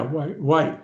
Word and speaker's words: Whyte: So that's Danny White, right? – Yeah, White, yeah. – Yeah. – It's spelled W-H-Y-T-E.